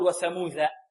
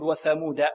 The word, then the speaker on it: وثمود: وكذلك هذه دال مهملة وليست ذال معجمة منقوطة، لا نقول وثموذ وثموذ. البعض يقول هكذا، يقول وثموذ الذين، لا، لأن قبلها ثاء وبعدها ذال في الذين، فربما يسبق اللسان إلى نطق الدال ذالا وهذا خطأ. فهكذا نقرأ، نقول وثمود ولا نقول وثموذ وثموذ وثمود.